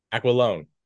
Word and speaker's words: Aquilone: Did I pronounce your last name correctly? – How did you pronounce it? – I'm sorry? – How did you pronounce it? I didn't hear. It was probably right, but I didn't notice. – I said James Aquilone? – Aquilone. – Aquilone. Okay.